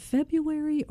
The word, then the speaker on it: February: And I was trying to figure out, is it February or February? It's one of those things. I was laying in bed thinking about that. – It's spelled February, but nobody says that.